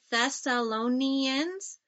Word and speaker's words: Thessalonians: Can you say Thessalonians? Thessalonians. That's a big word. It's a 5-syllable word.